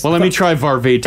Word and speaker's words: Varvatos: Apparently it's like Varvatos. – Varvatos? – Like Barbados. – Varvatos? But Varvatos? Oh, that sounds fancier. – Varvatos? – Varvatos. – Like Barbados. – Barbados. So the T turns into a D? – John Varvatos.